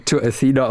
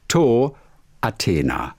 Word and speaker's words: To Athena: Ich habe ihn ja auch die letzten zwei Jahre immer To Athena ausgesprochen, aber es heißt To Athena.